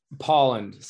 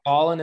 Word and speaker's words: Pohland: It's like Pohland. So it's like Pohland in the air with a D at the end.